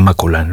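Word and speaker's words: Maculan: Su apellido, Valeria Maculán, se escribe sin acento, pero se pronuncia Valeria Maculán.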